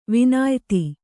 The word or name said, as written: ವಿನಯಿತ